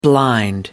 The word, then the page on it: blind: American English